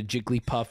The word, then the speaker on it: Jigglypuff: The word Jiggly has two syllables. The word Puff has one. When you combine them into Jigglypuff, it becomes a three-syllabled word.